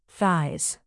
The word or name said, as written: thighs